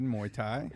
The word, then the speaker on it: Muay Thai: Did I pronounce that correctly? – Yes, you did. Muay Thai. – Muay Thai. Perfect.